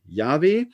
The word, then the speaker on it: Yahweh: mit denen schreibt man im Hebräischen diesen Gottesnamen JHWH, ausgesprochen Yahweh.